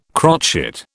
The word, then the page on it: crotchet: British English